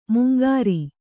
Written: ಮಂಗರೆ

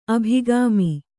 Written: ಅಭಿಗಾಮಿ

